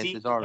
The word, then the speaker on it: Cesaro: Is it Cesaro? – Yeah, Cesaro. – S-A-R-O?